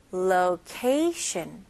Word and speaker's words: location: Location, location. Okay, 같이 해볼게요. Location. Shun. 마지막 T-I-O-N 발음은 S-H-U-N으로 한번 해볼게요.